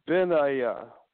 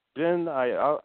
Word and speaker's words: Benaiah: One of them is Ben Ayah. Yah has built. The Hebrew name is Yah has built. That's what it means. Ben Ayah. It's Ben Ayah. I'll spell it. B-E-N-A-I-A-H.